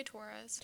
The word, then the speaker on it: Taurus: I say Taurus. – Taurus. – And sounds like we're saying tourist.